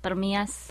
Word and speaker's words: Permias: Pronounce it just Permias.